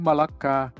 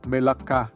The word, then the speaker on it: Melaka: Đã bay từ London về sân bay này và tại chính Malacca, mà trong tiếng Malay đọc là Melaka.